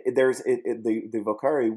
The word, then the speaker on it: vocare: Actually, is, it's pronounced vocare since it's Latin.